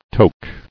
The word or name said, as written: Toque